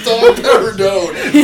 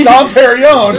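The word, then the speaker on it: Dom Pérignon: Oh, I guess I should have said dumper a dome. That's why I asked him. All right, the host. – I didn't got to read it. – The pronunciation. – Well, I even went over it. – For 3 minutes, you had to read this. – So it's Dom Perignon.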